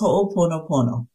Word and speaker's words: Ho'oponopono: and I may not be saying it right, but Ho'oponopono therapy. Am I saying that close to right? – It's Ho'oponopono.